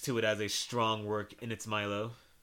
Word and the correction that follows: milieu: I totally did not pronounce that word correctly, by the way. – Milieu. – Milieu.